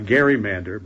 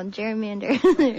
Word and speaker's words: gerrymander: And so they called it a gerrymander, but we've since changed the pronunciation over the years to gerrymander.